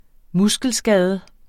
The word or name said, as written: muskelskade